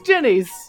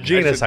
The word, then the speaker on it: Jeni's: Jenny's rhymes with... – penis. – Penis. Does that mean I have to go back? – Genus ice cream. – Genus ice cream. – It's spelled J-E-N-I-S.